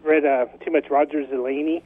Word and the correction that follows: Roger Zelazny: Am I pronouncing that right? – I've always said Zelazny, Roger Zelazny, Nine Princes in Amber.